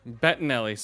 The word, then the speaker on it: Bettinelli: How do you pronounce that? Matt. – Bertinelli. – Valerie Bertinelli. – Bettinelli. – Bettinelli.